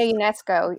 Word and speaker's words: Ionescu: Sabrina Ionescu.